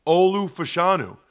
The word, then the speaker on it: Olu Fashanu: I mean, they're getting SEC bowl game experience for guys like Olu Fashanu. Is that how you say it? – That's the way I say it.